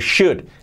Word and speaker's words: should: Lo mismo que could, pues should. La SH, sh, y luego la O no se pronuncia y la L tampoco. Should.